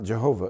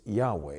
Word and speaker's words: Yahweh: It's not Jehovah. It can't grammatically be three syllables. Jehovah. That's three syllables. Syllables. Three. You can't have three syllables. It's improper Hebrew. But they did the best they could. But it's still improper Hebrew to translate this into Jehovah. It's Yahweh. It's only two syllables.